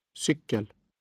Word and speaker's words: cykel: For now, learn to pronounce the words for sugar, water, and bicycle with accent one, a single rise. – Socker. Vatten. Cykel.